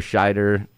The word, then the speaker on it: Scheider: I know a lot of purists don't like it, but uh, I liked Roy Scheider. and that was, that's how you pronounce it. Like Roy.